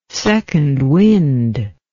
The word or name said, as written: second wind